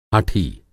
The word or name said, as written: हठी